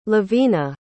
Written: lovina

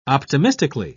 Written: optimistically

